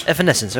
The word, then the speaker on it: Evanescence: Nummer 2, dat is hoe je de artiest uitspreekt. Hoe spreek je dat uit? Evanescence. – Evanescence, oké.